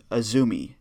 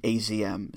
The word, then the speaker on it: AZM: It's Azumi, current high-speed champion, and it's spelled A-Z-M, but it's pronounced Azumi, not A-Z-M.